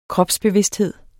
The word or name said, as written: kropsbevidsthed